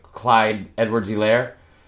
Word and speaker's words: Clyde Edwards-Helaire: I'm going to say his name, like Clyde Edwards-Helaire. – That was good. – Yeah, thank you. I didn't say hyphen. I usually say don't pronounce the hyphen. That's what I've read.